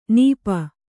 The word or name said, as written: ನಿಪ